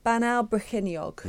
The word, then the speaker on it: Bannau Brycheiniog: I'm going to struggle to say it in Welsh, but it's going to from now from now on it's going to be called as Bannau Brycheiniog. – Yes, not bad. Not That's bad. A, not a bad pronunciation.